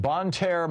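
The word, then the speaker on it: Bonne Terre: Picking up your calls here, Jeff in Bon Terre, Missouri. Am I saying that right, Jeff? – Yeah, you got it.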